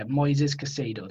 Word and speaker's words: Moises Caicedo: So anyway, I clicked on the account, and they're all chatting about this kid, Moises, or I think yeah, Moises Caicedo. That's how it's pronounced.